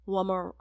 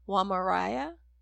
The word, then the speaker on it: Wamariya: War- Wamar. Oh, I can't say her name. Wamariya.